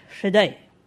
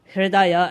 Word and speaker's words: Hridaya: Then if we look at the third word, Hriday, Hridaya, as many people pronounce, Hriday simply means heart.